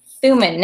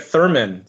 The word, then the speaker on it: Thuman: Chad Thuman. Now, am I butchering your last name? – No, you're pronouncing it perfectly. I'm very impressed. – Excellent. – I usually get Thurman, so...